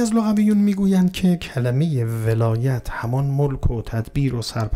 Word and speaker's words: ولایت: خب کلمه ولایت، ولایت اینجا با فتح است، ولایت.